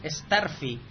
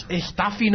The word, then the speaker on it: Starfy: Sí, y sí, parece ser que la pronunciación oficial es Starfy. Tiene bastante más lógica. – Sí. Uy, sí, lógica de Curro Jiménez, por lo menos. – Dios, Staffy no se llama más Staffy.